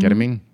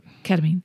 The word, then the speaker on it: ketamine: Ketamine? How do you pronounce that? – Ketamine.